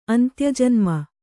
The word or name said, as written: ಅಂತ್ಯಜನ್ಮ